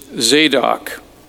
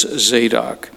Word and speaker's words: Zadok: The man's name is Zadok, Z-E-Z-A-D-O-C, Zadok. And I sometimes wondered how to say it because I don't speak Hebrew, but I looked it up and it is Zadok.